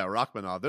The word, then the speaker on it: Rakhmonov: Rakhmonov. Yeah, Rakhmonov. There's like an extra H in there that I think that freaks people out.